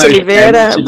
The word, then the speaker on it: Oliviera: Yeah, it's almost there, but it's Oliviera, but it's almost there.